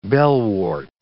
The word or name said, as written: bellwort